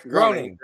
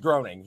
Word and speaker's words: Groening: It's Matt Groening, the Simpsons guy. – It is Groening.